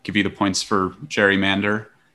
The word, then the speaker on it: gerrymander: He pronounced his last name with a hard G, but his namesake verb, a type of manipulative political practice, is typically pronounced with a soft G. Emily. – Gerrymander.